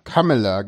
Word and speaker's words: Kamala: So, Kamala Harris mit U vorne aussprechen, bitte. – Mit O denke ich, Kamala. – Kamala, genau, Kamala.